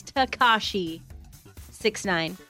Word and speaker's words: Tekashi 6ix9ine: That's how you say it. A lot of people, I guess, were Tekashi. – Oh, or Tekashi. I don't know. It's Tekashi 6ix9ine.